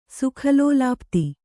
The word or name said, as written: ಸುಖಲೋಲಾಪ್ತಿ